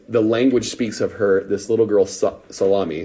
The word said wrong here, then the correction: Salome: is the best way to pronounce it, Salome,